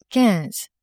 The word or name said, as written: quinze